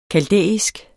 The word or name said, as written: kaldæisk